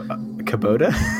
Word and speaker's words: Kubota: I'm guessing if it was orange, it was a uh, Kubato. Kubato, yeah. Kubata. Kubata. – Anyways, I don't think that's how it's pronounced. I think it's Kubo- Kubota.